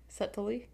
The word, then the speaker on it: subtly: Yeah, I was, like, trying to, like, subtly. – It's what, just like that. – What it subtly? Subtly? Well, there's a B in there. – Yeah, subtly. Yeah. Subtly. It's a silent B.